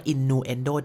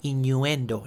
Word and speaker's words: innuendo: ไม่ออกเสียงว่า innuendo แต่เป็น innuendo นะครับ